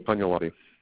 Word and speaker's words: Cagnolatti: C-A-G-N-O-L-A-T-T-I. – Okay. How do you pronounce it, Chris? – Pagnolotti.